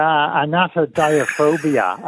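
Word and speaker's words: anatidaephobia: I think, is it pronounced anatophobia? It's the irrational fear that somewhere a duck or a goose is watching you. – Yeah, anatodiaphobia, a fear of ducks watching you.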